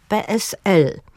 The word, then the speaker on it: PSL: I tego typu formy akcentujemy zawsze na ostatniej sylabie. Na przykład Polskie Stronnictwo Ludowe, czyli PSL. Szkoła Główna Handlowa, czyli SGH.